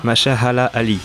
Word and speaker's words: Mahershala Ali: Mahershala Ali, voilà.